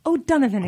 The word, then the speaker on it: O'Donovan: O'Donovan, exactly. O'Donovan. You just have to pretend you're like an old Irish man.